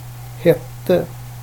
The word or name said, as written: hette